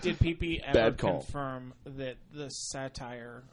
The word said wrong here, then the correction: satyr: or the... – Satyr? – The Satyr Shyman.